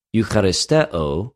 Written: εὐχαριστεω

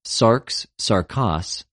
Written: σαρξ,σαρκος

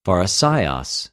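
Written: Φαρισαῖος

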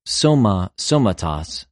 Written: σωμα,σωματος

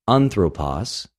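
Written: ἀνθρωπος

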